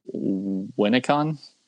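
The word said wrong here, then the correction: Winneconne: Winnicani? – Oh, the E is pronounced?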